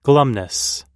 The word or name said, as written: glumness